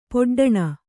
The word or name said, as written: ಪೊಡ್ಡಣ